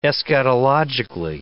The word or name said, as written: eschatologically